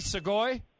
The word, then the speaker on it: sugoi: All right, so we're saying it correctly, sugoi.